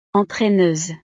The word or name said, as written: entraineuse